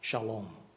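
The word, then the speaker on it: shalom (שלום): 那么以色列人呢，就用一个词来描述，这个词呢叫做平安，它的希伯来文的发音叫做 shalom，完整无缺，非常的和谐，没有任何的破碎。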